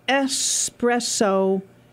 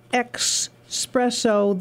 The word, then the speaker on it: espresso: The strong coffee drink brewed into a tiny cup is pronounced with an S in the first syllable. It is espresso. It is not expresso. There is no X. It is espresso. Forget about the E and just say espresso.